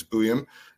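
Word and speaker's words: Buium: I figured out how to pronounce it finally after a year of trying. It's Buyum.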